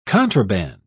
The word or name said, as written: contraband